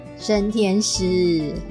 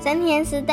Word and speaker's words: 升天师: 是生鲜包带他去。那个生什么的，我一直念成生鲜包。 – 升天师。 – 升天师带他去的。